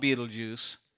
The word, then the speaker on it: Betelgeuse: It's not spelled like the movie from the 80s, but it's called Betelgeuse.